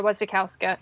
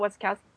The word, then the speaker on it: Wasikowska: I wasn't disappointed with the actress, with Mia Wasikowska. Oh, Wasikowska. I always say her last name wrong.